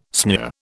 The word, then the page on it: sneer: British English